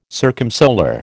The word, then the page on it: circumsolar: American English